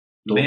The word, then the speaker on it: daube: Also zum einen als, ich glaube, es gibt's als Schmorgericht sehr und das ist diese spricht sich, also geschrieben wird D-A-U-B-E, also Daube, Daube. mais c'est français, c'est la Daube.